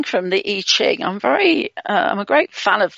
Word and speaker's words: I Ching: If you're interested in the I Ching, it's the letter I, pronounced E, and then Ching, C-H-I-N-G.